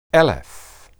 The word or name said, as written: eleph